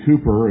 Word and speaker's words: Cowper: There's a man named William Cooper. It's spelled like Cowper, C-O-W-P-E-R, who was a very good friend of John Newton.